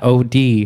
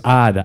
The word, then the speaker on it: Odd: It's called the Odd Podcast, OD Podcast, but not OD. It's Odd. Odd Podcast. You don't say the O and the D. Just the abbreviation.